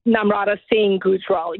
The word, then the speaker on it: Namrata Singh Gujral: Namrata Singh Gujral.